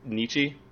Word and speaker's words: Nietzsche: Okay, so Nietzsche is actually pronounced Nietzsche.